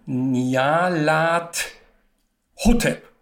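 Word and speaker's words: Nyarlathotep: Und da kommt keiner auf Nyala Vorteil, na doch, kommen auch manche, ist aber Quatsch. Richtig wäre Niala oder also Nialat Hotep.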